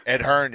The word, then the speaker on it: Ed Hearn: Or David Kohner for Ed Herron. – Ed Hearn, yeah. – Oh, Ed Hearn, yeah. – Can't pronounce his name right.